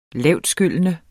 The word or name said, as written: lavtskyllende